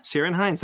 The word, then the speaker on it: Ciarán Hinds: The Lord of the Rings, the Rings of Power has cast Sirian. – Ciarán Hinds. – Ciarán Hinds, thank you.